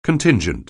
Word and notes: contingent: anglais

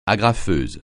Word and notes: agrafeuse: French

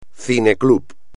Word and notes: cineclub: espagnol

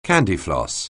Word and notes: candyfloss: anglais

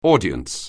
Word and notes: audience: anglais

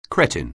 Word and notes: cretin: anglais

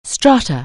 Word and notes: stratum: anglais

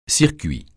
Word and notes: circuit: francais

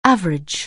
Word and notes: average: anglais

